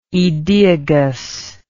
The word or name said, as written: aedeagal